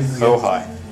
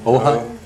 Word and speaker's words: Ojai: Ojai. – Ojai? – Yeah, it's spelled the Spanish way, O-J-A-I. – O-J-A-I, Ojai.